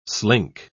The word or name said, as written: slink